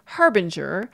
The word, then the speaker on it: harbinger: You have the standard pronunciation here of harbinger.